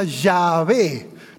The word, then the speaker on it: Yahvé: El nombre de Dios se traduce como Señor en muchas de las versiones de la Biblia, con mayúsculas, y en el hebreo se pronuncia Yahvé.